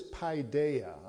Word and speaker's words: paideia: This word is paideia. I'll spell it for you. P-A-I-D-E-I-A.